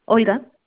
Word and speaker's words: Olga: Olga.